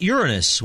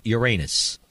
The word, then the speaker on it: Uranus: Some people would pronounce that Uranus, which is the way you pronounce the planet. In the little settlement, they pronounce it Uranus.